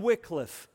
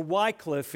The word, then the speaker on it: Wycliffe: John Wycliffe, or Wycliffe, if you prefer that pronunciation.